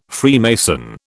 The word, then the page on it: Freemason: British English